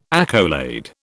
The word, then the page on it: accolade: British English